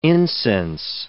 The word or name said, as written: incense